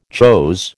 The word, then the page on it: chose: American English